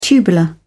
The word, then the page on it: tubular: British English